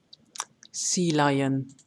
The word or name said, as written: Sea lion